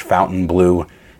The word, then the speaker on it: Fontainebleau: So I know the spelling is weird, or, you know, it's the French spelling, but it is pronounced like English Fontainebleau, and we are doing it right.